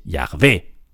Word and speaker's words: Yahvé: Y los especialistas en hebreo, en antiguo hebreo y en, la Biblia, en el Antiguo Testamento, nos dicen la pronunciación más probable de YHWH es Yahvé.